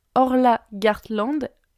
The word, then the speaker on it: Orla Gartland: Je vais vous parler de Orla Gartland. Son prénom c'est O-R-L-A et Gartland comme ça se prononce.